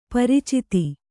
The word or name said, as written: ಪರಿಚಿತಿ